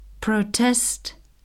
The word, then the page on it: protest: verb